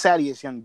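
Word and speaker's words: Thaddeus Young: Cynthia's Young. – Exactly, that, you said it wrong. It's Sadius Young, dumbass.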